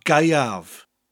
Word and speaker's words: Gaeaf: Then we have Galan, spelled G-A-L-A-N, pretty much pronounced as you spell it. Galan. Galan. And then we have Gaiav, spelled G-A-E-A-F. The A-E in Welsh gives you the I sound, Gai, and the F at the end of the word gives you a V sound, Gaiav.